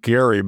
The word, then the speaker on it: Gerry: And you should pronounce it Gary, by the way. Not that anybody does, but that's the correct pronunciation.